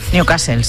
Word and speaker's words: Newcastle: Newcastle, sí, sí, sí.